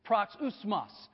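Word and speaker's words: proxusmos (παροξυσμός): The original word is pronounced this way, proxusmos. Phonetically, it'd be spelled proxusmos, all right?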